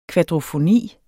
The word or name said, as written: kvadrofoni